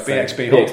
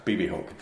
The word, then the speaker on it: BxB Hulk: Magnitude Kishiwada, Big Boss, M-A-G-M-A. So, because I always worry about how to pronounce these, because I've been for ages pronouncing BB Hulk wrong. – Yeah. – Because like, it's BXB Hulk. – BXB Hulk. – No, it's BB Hulk.